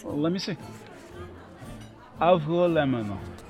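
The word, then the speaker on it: avgolemono: It's not really a hard G sound. It's more swallowed. Avgo lemono.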